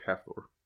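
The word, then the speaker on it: Hafþór: I have no frame of reference for Icelandic spelling, but it's spelled HAF, and then it's a PB stuck together. So it's like the B line up and the P down. Yeah. O-R. And it is pronounced like Half Thor.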